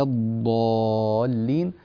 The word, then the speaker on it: الضالين: ولا الضالين، مخرج الضاد الصحيحة يكون من جوانب اللسان، هذه المنطقة تسمى في التجويد أو عند علماء اللغة حافة اللسان، هذا هو المطلوب. لكن الضاد التي تخرج من طرف اللسان هذه لا تصلح، لا تقول ولا الضا ضا، هذه دال مفخمة ولا تصلح هذه الضاد في النطق، فتنبه إلى هذا الأمر.